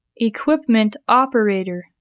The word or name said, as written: equipment operator